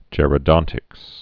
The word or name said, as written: gerodontics